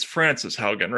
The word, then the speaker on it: Frances Haugen: So, okay, so first of all, who is it's Frances Haugen, right?